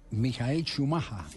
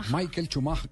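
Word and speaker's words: Michael Schumacher: que es donde está recluido Mijael Schumacher así, es la pronunciación eh, original en alemán. – Michael Schumacher, se dice en inglés.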